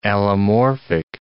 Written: allomorphic

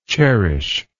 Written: cherishingly

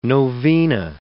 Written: novena

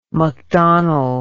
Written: mcdonald

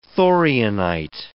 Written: thorianite

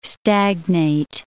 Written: stagnate

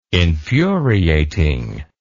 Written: infuriating

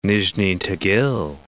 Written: Nizhni Tagil

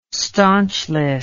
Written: stanchless

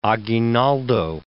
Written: Aguinaldo

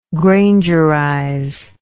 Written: grangerize

